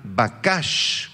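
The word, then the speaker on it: bakash (בקש): Esta es la palabra hebrea bakash, con B grande, Q y acento en la segunda A.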